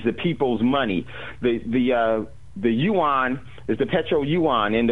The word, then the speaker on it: Yuan: Am I saying that correctly? Is it Yuan? How do you say it? Yuan? – Yeah, the Petro, oh shoot, the Petro Yuan, yes.